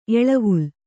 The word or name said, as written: ಎಳೆವಲೆ